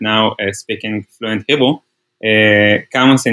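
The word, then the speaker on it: Kamus: Camus, which I'm almost certainly mispronouncing. There's some people going to say it's called Camus. Some will say Camus. It's K-A-M-U-S, which out of the gate is already a better name than almost anything Amazon has ever given to anything.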